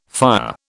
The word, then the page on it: fire: British English